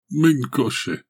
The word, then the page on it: men ko she: Galindri